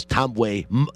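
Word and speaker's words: Ntambwe: And blazing, as you heard a Joel Tomboy three-pointer. That is Tomboy. The N is silent. All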